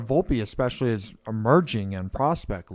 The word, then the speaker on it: Volpe: Am I saying it right with Volpe? – Yeah, Anthony Volpe.